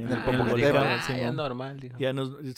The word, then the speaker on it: Popocatépetl: Popocatépetl. – Popocatépetl.